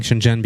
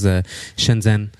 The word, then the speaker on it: Shenzhen: And I'm saying Shenzhen because this is the way that it's pronounced in Mandarin, but most of the foreigners know it as Shenzhen.